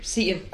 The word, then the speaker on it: Seton: Seton. – Okay. – Seton Hall, yeah.